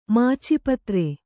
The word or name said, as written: ಮಾಚಪತ್ರೆ